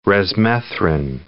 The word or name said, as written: resmethrin